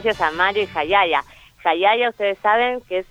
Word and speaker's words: jallalla: Sí, se escribe J-A-L-L-A-L-L-A, jayaya, y se pronuncia jayaya.